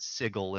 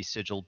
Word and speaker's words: Sigil: There was a city called the City of, they pronounce it Sigil in the game. Usually it would be Sigil,